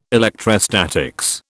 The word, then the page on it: electrostatics: British English